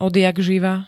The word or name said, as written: odjakživa